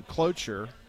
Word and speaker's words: cloture: The earliest that he could do cloture is on Thursday. Okay. I don't know why they don't call it closure, but it's cloture. It's a French term. I'm not saying it wrong. It's C-L-O-T-U-R-E.